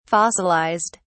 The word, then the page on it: fossilised: British English